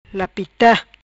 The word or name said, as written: Lapita